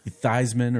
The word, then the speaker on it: Theismann: Theismann rhymes with Theismann or Heisman.